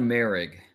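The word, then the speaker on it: Moehrig: We're talking about TCU safety Trayvon, and then you have to help me with the last name because it is pronunciation season. – Trayvon Merrig. – Yes, there's a little bit of an A sound in there.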